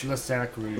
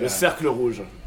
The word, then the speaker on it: Le Cercle Rouge: Say that again, what rouge? – Le Cercle Rouge? – It's Le Cercle Rouge. – Le Cercle? – Okay, yeah. Le Cercle Rouge.